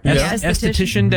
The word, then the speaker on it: Esthetician: National Esthetician. Am I saying that right? – Esthetician Day. – Mm-hmm. Yeah, that's it.